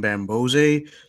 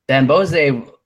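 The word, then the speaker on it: Bamgbose: Yeah, I don't know if the G is silent because the commentators yesterday were saying Bambose, so I don't know.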